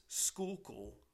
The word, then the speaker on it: Schuylkill: and how to go about pronouncing the river is Schuylkill, and that is spelled, folks, S-C-H-U-Y-L-K-I-L-L. It's a funny way of pronouncing it, but believe me, that's how you pronounce it, Schuylkill.